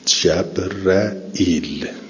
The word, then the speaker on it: جبرئیل: و قرأ حمزه و الکسائی و ابوبکر الا یحیی جبرئیل، که معمولا هم ما اینجوری تلفظ میکنیم جبرئیل به فتح الجیم و را محموزن.